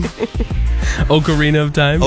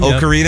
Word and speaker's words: Ocarina: Ocarina of Time. – Ocarina, yeah.